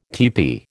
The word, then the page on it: teepee: American English